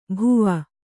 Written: ಬುವಾ

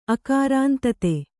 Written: ಆಕ್ರಂದಿತ

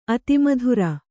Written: ಅತಿಮೂತ್ರ

